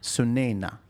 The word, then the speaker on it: Sunaina: How do you pronounce it again? – Sunaina. Yes.